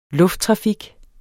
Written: lufttrafik

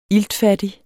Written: iltfattig

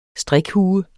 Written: strikhue

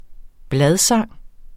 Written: bladsang

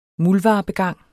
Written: muldvarpegang

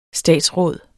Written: statsråd

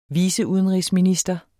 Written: viceudenrigsminister

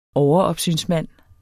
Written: overopsynsmand

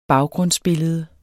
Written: baggrundsbillede

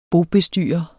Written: BOBESTYRERE

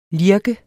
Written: lirke

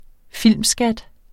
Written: filmskat

